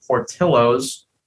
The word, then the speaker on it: Portillo's: And today we're covering Portillo's. I think that's how we say it, right, Ian? You've been there, is that how you pronounce it? – Yep, that's how you pronounce it. – All right. They're not using the Spanish accent correctly, but that's okay.